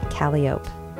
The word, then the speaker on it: Calliope: Calliope is pronounced Calliope.